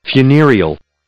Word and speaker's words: funereal: Did I say it correctly? – Funereal. – Funereal. Funereal. Cool.